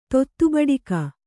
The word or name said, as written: ತೊತ್ತುಬಡಕ